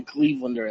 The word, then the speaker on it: Clevelander: I mean, how do you say it? A Clevedonian, a Clevelander? – I use the term Clevelander, and that's what I've heard other residents use.